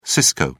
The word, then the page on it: cisco: British English